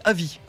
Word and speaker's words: Avy: Et on finira avec Avi, A-V-Y, je le prononce comme ça, parce que ça nous vient de Lyon.